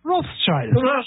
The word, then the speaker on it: Rothschild: How do you pronounce red shield in German? Oh, yeah, that's right. Rothschild.